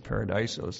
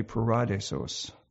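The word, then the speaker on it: paradisos (παράδεισος): Sometimes the Greek is kind of weird the way it's pronounced. I would have said paradisos. That's what most people would say, but it's actually paradisos.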